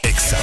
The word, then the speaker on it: Exa: Exa FM 99.7. No se dicen éxitos, se dice Exa.